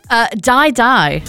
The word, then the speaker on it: Dai Dai: How am I pronouncing this? – Die Die. – But it's spelled D-A-I-D-A-I.